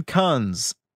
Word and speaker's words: Kunz: He's played by the actor, Simon K-U-N-Z. I got to be very careful why I pronounce that. Simon Kunz. Yes, I'll go with that. Kunz.